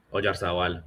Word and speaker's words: Oyarzabal: Oyarzabal.